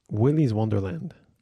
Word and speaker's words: Willy's Wonderland: Willy's Wonderland.